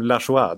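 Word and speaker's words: Lajoie: Om man skriver sitt namn Lajoye och vill ha det uttalat som Lachua, då får man skylla sig själv alltså.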